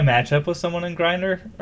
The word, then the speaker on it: Grindr: It's pronounced Grindr.